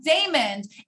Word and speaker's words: Daymond: And it's at Daymond John, isn't it? That's your Instagram, right? At the Shark Daymond. And my name is spelled like Raymond with a D.